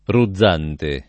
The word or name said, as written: Ruzzante